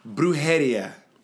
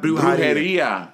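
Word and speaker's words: Brujería: I always say it wrong. Brujeria. Not Brujeria. Brujeria.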